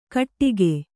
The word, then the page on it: ಘಟ್ಟಿಕೆ: ghaṭṭike; Kannada